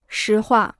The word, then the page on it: 实话: shí huà